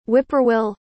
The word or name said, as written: whip-poor-will